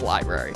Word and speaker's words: LBRY: So I want to talk about the ruling that recently came down in SEC v.LBRY, which is spelled L-B-R-Y, if you've seen it online. It's pronounced library.